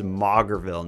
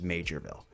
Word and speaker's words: Maugerville: So we talked about the potato man of what I refer to as Maugerville, New Brunswick. I got updates or got feedback like crazy that it's spelt like Maugerville, but it's actually pronounced Majorville.